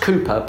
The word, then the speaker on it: Cowper: It's pronounced Cooper, but it's spelt Cowper.